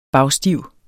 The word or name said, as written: bagstiv